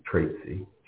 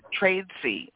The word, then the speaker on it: Tradesy: And I have to tell people that if you go to, and how do you pronounce, is it Tradesy? Is that the correct way to pronounce that? – Actually, it's Tradesy, Tradesy. – Trade C. Okay. – Yes. – See, the little Lutheran school girl wants to pronounce all of the, you know, letters in the name.